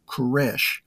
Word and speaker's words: Koresh: Cyrus in Hebrew is pronounced Koresh.